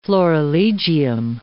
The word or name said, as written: florilegium